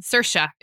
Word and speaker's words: Saoirse: Saoirse. It rhymes with it. Inertia. And I know that because she said it in an awards show once.